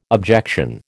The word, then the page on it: objection: American English